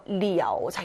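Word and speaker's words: 리아오 (了): 동사, 더, 결과보어, 방향보어, 리아오. 자, 요거 중요합니다. 이 가능보어에서 러라고 읽지 않고 리아오라고 읽는 거, 요거 다음 자에서도 봤었어요.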